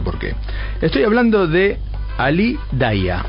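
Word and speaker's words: Ali Dia: Bueno, se escribe, el apellido es Dia, pero bueno, los ingleses le dicen Ali Daia.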